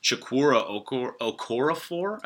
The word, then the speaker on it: Chukwuma Okorafor: Chukwuma Okorafor, I believe is how you say his name.